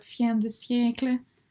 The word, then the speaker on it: fin de siècle: And the end of the fin de siècle. Yes, it's pronounced like that with that accent as well.